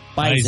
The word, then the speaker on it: Bison: Bison is the way they pronounce it. – Yeah, they're picky about how they pronounce that. Yeah. It's like a Z. You know? – Yeah. Bison. – Even though it's spelled with an S, but whatever.